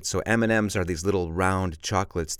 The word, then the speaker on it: M&M's: And remember, we pronounce this like it's an N. We don't say usually M and M's. We say M&M's, which, by the way, are pretty good, aren't they?